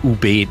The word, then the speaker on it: Ube: Das E wird etwas stärker betont, also Ube. Die meisten sagen Ube. Also korrekt wäre es Ube.